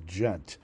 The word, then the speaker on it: Ghent: researcher at Ghent University, it may be pronounced Gent, over remarks that breached the country's anti-racism law, De Morgan reported yesterday, Thursday.